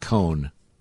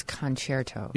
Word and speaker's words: concerto: Yeah, but it's not cone. – It's concerto. – Yeah. – Right, but didn't you just say cone? – Yeah, but I'm saying the pronunciation just as a helpful guy. Here, how about this? Con. Yeah. An ex-con tried to sit in a chair and he broke his toe. Concerto.